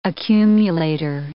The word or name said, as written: accumulator